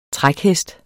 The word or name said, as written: trækhest